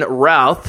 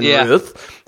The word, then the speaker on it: Routh: We screwed that up, and we screwed up Brandon Routh, who we've been calling Brandon Ruth.